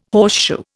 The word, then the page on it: horseshoe: Indian